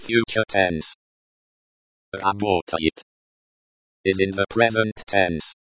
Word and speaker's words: работает: Работает. Работает, emphasis on the second syllable.